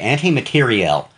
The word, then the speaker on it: materiel: It's pronounced, it is spelled materiel. – Okay, so two L's and an E? – Yes. – Okay, okay. – I believe. – I can't remember if it has the two L's and E, right. But it is pronounced anti-materiel.